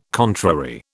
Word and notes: contrary: British English

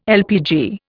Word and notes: LPG: Indian

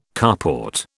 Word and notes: carport: British English

